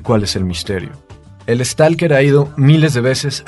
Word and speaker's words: stalker: Los personajes que van son un escritor y un científico, y van guiados por el stalker, es decir, el explorador, que yo lo he dicho stalker porque así se dice en ruso, es como la mala pronunciación de la palabra inglesa en ruso.